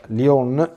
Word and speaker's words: Lyon: Tutti quanti dicono Lione, ma Lione in francese a casa mia si pronuncia Lyon, quindi è meglio essere sempre fedeli alle lingue quando si parlano altre lingue, non fare le italianizzazioni.